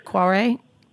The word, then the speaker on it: Kvarøy: And am I pronouncing that right? Kvarøy? – Yeah, yeah. – Okay. Yes, you are.